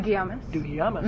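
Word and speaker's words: Dougiamas: but that podcast Tim Wilson did with Martin Dugalmas, is that how you say it? – Dugiamas.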